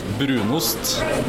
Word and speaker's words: Brunost: Yeah, in Norway we call it Brunost, which is brown cheese, or Gjetost, which is goat cheese.